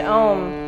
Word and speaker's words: Om: So if you're gonna chant Om, chant the O and the M, because the O and the M actually represent the union of the feminine and the masculine in our bodies.